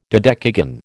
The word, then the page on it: dodecagon: American English